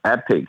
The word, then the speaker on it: Epoch: That's E-P-O-C-H. Someone pronounced as epoch, someone pronounced as epic. It's just a Canadian way or American way to pronounce that word differently.